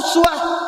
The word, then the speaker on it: uswah: Dan dahsyatnya keteladanannya disebutkan dengan dua cara baca, satu uswah, kedua iswah.